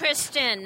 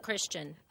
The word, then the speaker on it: Kristen: It's Kristen, not Christian.